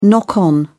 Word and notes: knock on: British English

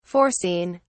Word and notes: foreseen: British English

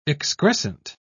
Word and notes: excrescent: British English

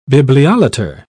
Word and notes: bibliolater: British English

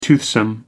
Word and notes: toothsome: American English

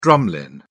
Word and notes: drumlin: British English

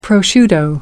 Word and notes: prosciutto: American English

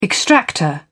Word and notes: extractor: British English

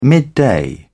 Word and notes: midday: British English

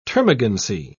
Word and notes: termagancy: British English

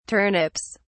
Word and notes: turnips: British English

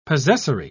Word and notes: possessory: British English

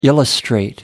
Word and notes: illustrate: American English

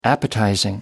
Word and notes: appetizing: American English

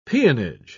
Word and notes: peonage: British English

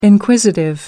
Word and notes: inquisitive: American English

